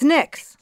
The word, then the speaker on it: NYX: It's NYX. – Yep. NYX is goddess of night. – Okay. – From Greek mythology. Like, even the word night originated from the word NYX.